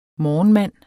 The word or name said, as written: morgenmand